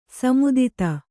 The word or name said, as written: ಸೀಮಿತತೆ